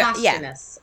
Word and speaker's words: posthumous: Posthumous. Yeah. I was saying a posthumous, but it's posthumous.